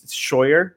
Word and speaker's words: Scheuer: It's Scheuer. It's like German. And it just, and it's spelled, it's not spelled how it sounds.